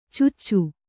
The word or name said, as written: ಚಚು